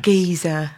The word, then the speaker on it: Giza: I want to go to Giza. – Yes. Yes. Mm-hmm. Is that how you pronounce it? – Yes. Giza.